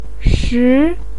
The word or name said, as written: shi2